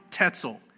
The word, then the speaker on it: Tetzel: It's fascinating to me that there was this Catholic teacher, Johann Tetzel. Little kids, it rhymes with pretzel, okay? Johann Tetzel.